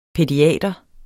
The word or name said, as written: pædiater